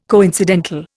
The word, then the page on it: coincidental: Indian